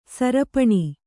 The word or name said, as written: ಸೀರ್ಪನಿ